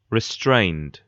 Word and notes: restrained: British English

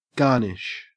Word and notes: garnish: Australian English

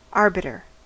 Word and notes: arbiter: American English

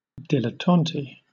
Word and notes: dilettante: British English

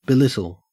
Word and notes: belittle: Australian English

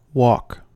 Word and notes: wok: American English

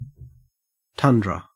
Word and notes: tundra: Australian English

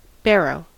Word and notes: barrow: American English

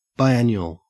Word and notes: biannual: Australian English

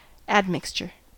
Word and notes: admixture: American English

